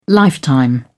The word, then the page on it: lifetime: British English